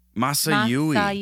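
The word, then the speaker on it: Masayuki: It was directed by Masayui Kojima. Hopefully I pronounced that correctly. – Masayui. – That's much better. Anyway. – Got to separate out those syllables.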